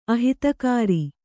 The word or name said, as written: ಅಹಿತಕಾರಿ